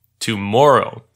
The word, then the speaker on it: tomorrow: Normally, we stress the second syllable of the three syllables of that word tomorrow. In the song, the last syllable is stressed, making it sound like tomorrow.